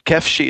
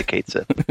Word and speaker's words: Cait Sith: Kefshi is the correct pronunciation, but most people would say Kate Sith. – C-A-I-T-S-I-T-H.